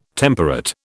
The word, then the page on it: temperate: British English